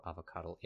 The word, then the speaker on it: Avocuddle: It's just A-V-O and then C-U-D-D-L-E. – A-V-O. Oh, okay. So like, okay, I get it. – Like avocado, Avocuddle.